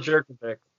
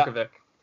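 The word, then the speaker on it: Jurkovec: How do you say his last name? Jerkic? – Phil Jerkovic. – Yeah. Jerkovic.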